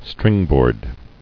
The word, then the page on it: Stringboard: string·board